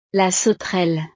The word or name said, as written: la sauterelle